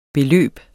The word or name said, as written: beløb